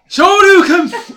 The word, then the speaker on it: Shoryuken: Shoryuken!